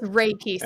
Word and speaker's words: Reiki: That's how it's said. Reiki. So okay. Like Ray and Ki okay. Together.